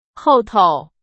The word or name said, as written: 后头